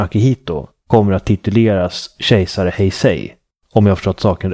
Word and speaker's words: Heisei: Och nu så lever vi i eran Heisei.